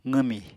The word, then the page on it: qwmi: apatani